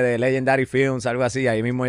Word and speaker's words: Legendaddy: Eh, la pronunciación es como en inglés, pero cuando tú ves el nombre, uno es Legend y el nombre mío y es... Legendary. Legendary.